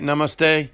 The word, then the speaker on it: Namaste: last week when I was doing Hanukkah in Namaste. Am I saying it right? Namaste.